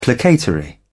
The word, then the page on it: placatory: British English